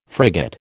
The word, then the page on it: frigate: American English